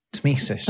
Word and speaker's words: tmesis: One that is really quite difficult to pronounce is Temesis. Temesis. – Oh, yes. – T-M-E-S-I-S.